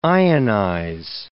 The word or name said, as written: ionize